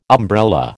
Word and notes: umbrella: American English

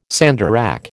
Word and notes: sandarac: American English

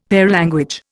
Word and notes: paralanguage: Indian

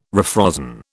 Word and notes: refrozen: British English

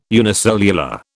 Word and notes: unicellular: British English

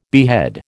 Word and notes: behead: American English

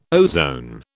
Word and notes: ozone: British English

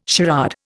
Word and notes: charade: Indian

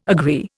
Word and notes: agree: Indian

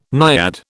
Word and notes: naiad: British English

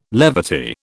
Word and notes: levity: British English

